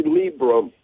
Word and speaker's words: Librum: So, Dr. Librum, am I pronouncing your name correctly? – It's actually Librum. – Oh, thank you. I was saying it phonetically, L-I-E, but Dr. Librum, thank you for being here with us this morning.